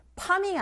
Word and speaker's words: farming: Farming 아닌 거 아시죠? F 발음이 이게 잘 안 나오는 경우가 있어요. 입 모양을 잡아 주셔야 됩니다. 윗니로 아랫 입술을 살짝 긁어 주시면 돼요. F, F, F 하면서 farming 이렇게요.